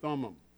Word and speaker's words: Thummim: Two different pronunciation helpers said it differently. So I'm saying Urim and Thummim.